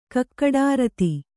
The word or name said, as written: ಕಕ್ಕಡಾರತಿ